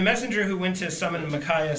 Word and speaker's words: Micaiah: Micaiah? I heard it said several times yesterday, and I still struggle. Micaiah? – Isaiah's got the same spelling, and we say Isaiah. – Micaiah?